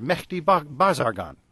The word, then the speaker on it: Mehdi Bazargan: There was a prime minister, Mehdi Bazargan.